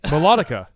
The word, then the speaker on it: melodica: Melodica? How do you pronounce it? – I'm going to read that. – I failed English, all right? – Melodica. – Melodica. – Like a melody, melodica.